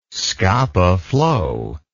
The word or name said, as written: scapa flow